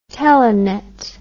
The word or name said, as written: telenet